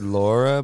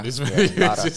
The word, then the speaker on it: Lara: I probably said Laura, but it's probably Lara.